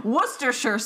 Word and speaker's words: Worcestershire: Worcestershire sauce is how you say it.